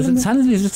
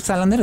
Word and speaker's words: Salander: Lisbeth Salander o Salan, o cómo se llama, Salander. – Salander, es que lo, lo pronuncian así.